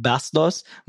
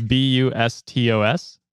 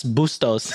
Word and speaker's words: Bustos: And I don't know if I didn't see the comment, and I don't know how their name is spelled, but if they're Filipino and you just call them Pam Bastos, Bastos means disgusting in Tagalog. So hopefully you pronounced their name right. – It's Bustos. – That's Bustos.